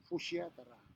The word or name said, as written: fushiatara